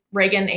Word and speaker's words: Rehgan: But you can find me on LinkedIn, Rehgan Avon. My name is spelled R-E-H-G-A-N.